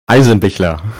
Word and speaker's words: Eisenbichler: Eisenbichler.